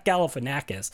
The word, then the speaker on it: Galifianakis: And how much of that comedy is Galifianakis, or my goodness, I butchered that word. – That was pretty close. – Anyway, Zach Galifianakis, just like blurting out absurd quotes, right?